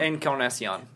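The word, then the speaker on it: Encarnación: So no, well, Bautista, and how do you pronounce it? Encarnacion? – Encarnacion.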